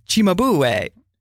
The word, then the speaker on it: Cimabue: Cimabue, most people remember because that's how you learned how to pronounce Italian words, because his name is spelled C-I-M-B-U-E. Cimabue. No, Cimabue. Okay, not important.